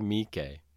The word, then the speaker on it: Miike: Sure, it's Takashi Miike. – Miike, Jesus Christ.